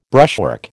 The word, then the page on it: brushwork: American English